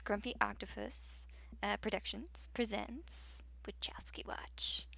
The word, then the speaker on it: Wachowski: Yes, Wachowski. – Yes, there's a ski on the end. – Yeah, there is. Wachow. – It's not just Wachow.